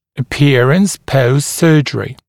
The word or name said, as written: appearance post surgery